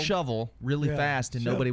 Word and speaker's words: Shelbyville: Just uh, down the road in Bedford County, we have Shelbyville, Shelbyville, Shelbyville, not Shelbyville, but Cheville.